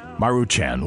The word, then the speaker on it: Maruchan: Number 2 in our favorite poverty meals, the how do you say that? M-A-R-U, Maruchan. Let's go with Maruchan ramen noodles.